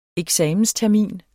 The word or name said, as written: eksamenstermin